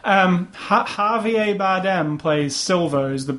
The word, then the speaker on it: Javier: That's how you say it. Javier.